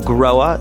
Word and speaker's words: Grohe: The company is called Groa. It's spelled G-R-O-H-E.